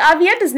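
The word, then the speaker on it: Aviendha: No, because, you know, there's a lot of words in the Wheel of Time that don't necessarily sound like they're spelled, or like Avienda's name actually has an H in it, yeah. Right? It's got that H. People say it's a silent H. Really, it's just a soft H. Just nobody pronounces it.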